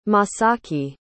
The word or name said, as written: Masaki